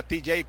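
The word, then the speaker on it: Tejay: Se pronuncia TJ como Tijuana abreviado, pero él escribe diferente. Su nombre es Tejai con Y. TJ, la fonética.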